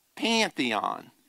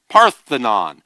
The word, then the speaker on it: Parthenon: The structure on the top is the Pantheon. Is that how you say it? Parthenon.